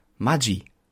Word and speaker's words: Maggi: Das DSCH, das G sozusagen, kommt, wenn ein E oder ein I nach dem G kommt. Maggi. Das ist eigentlich die korrekte Aussprache.